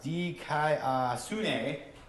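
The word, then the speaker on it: dikaiosune (δικαιοσύνη): So, in the Greek, the word for righteousness and the word for justice is pronounced dikaiosune. Dikaiosune, and it's the same word.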